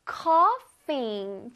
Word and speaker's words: cough: I can't stop coughing. 좋아요. GH가 만나면 F 발음인 거 기억하세요.